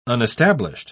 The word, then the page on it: unestablished: British English